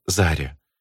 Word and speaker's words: Заря: «Не нравится?» «Это, конечно, не Зорька, но лучше пусть будет Заря», заявила моя подруга, сделав ударение на первом слоге.